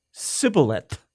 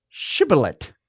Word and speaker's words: Shibboleth: And so the people from Ephraim would say, "Oh, that's Sibboleth." But the Ephraimite people, Gileadite people pronounced it with an SH at the beginning, Shibboleth.